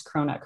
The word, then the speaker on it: Cronut: But in particular, I've been reading about a case where you carried out brain surgery on a sea lion called Cronut, and I don't know if I've pronounced that right, but could you tell our listeners more about Cronut's story? – Yeah, you did pronounce Cronut correctly, and he was named after a sort of American dessert that's a cross between a donut and a croissant, like.